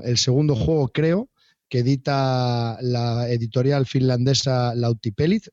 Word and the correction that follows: Lautapelit: me parece que se llama, si lo digo bien, y si se me carga la página. Lautapelit. – Lautapelit. Sí, se pronuncia así es de cuatro, de cuatro autores nórdicos y lo vamos a dejar así.